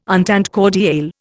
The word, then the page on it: entente cordiale: Indian